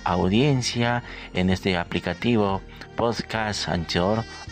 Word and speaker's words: Anchor: encuentra como siempre en esta aplicación, como se dice en la pronunciación en español, Anchor, pero en inglés se dice Anchor.